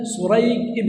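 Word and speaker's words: سريج: فعندنا شريح ابن النعمان وعندنا سريق ابن النعمان، الآن الاتفاق خطا ونطقا فيما يتعلق بمن؟ بالآباء. وحصل فيما يتعلق بالأبناء ما هو؟ الاختلاف في النطق دون الخط. عندنا سريق وعندنا شريح، الخط واحد لكن النطق ماذا يختلف؟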